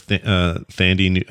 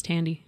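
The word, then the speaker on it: Thandie: I think it's Tandy. – Is it Tandy? – Mm-hmm. – Like the computer? – I'm not really sure how she pronounces it. The Radio Shack computer.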